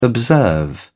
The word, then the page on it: observe: British English